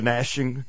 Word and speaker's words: gnashing: Yeah, I love that word, gnashing. I know that the G is silent.